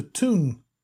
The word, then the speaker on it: Toon: Newcastle are known locally as the Toon, that being the local pronunciation of the word town.